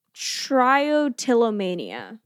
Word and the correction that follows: Trichotillomania: is what I would say. – Trichotillomania. – Trichotillomania.